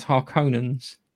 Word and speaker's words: Harkonnen: I've always seen them read and heard them read as Harkonnens. But they call them Harkonnens. – Harkonnens, we own them. Because I always thought it was Hark, we own them. Yeah, Harkonnen.